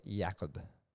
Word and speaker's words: Jacob: there's a character in there called Jacob. – Okay. – So unless he's a fan of Sex Education, there is no excuse for doing a soft J at McDonald's.